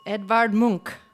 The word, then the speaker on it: Edvard Munch: Edvard Munch.